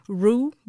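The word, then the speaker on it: Reaux: Rue Rose on Sunday of last week. – Um, Rue like a gravy, ain't it? – That's R E A U X. – Oh, Rue.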